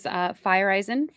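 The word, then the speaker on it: Feyereisen: The correct pronunciation is Fireisen for our reliever, JP.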